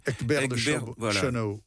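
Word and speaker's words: Egbert de Schönau: Alors, le terme cathare est tiré des écrits de saint Augustin, hein, par le moine, alors je prononce mal, Egbert de Chenau.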